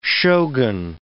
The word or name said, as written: shogun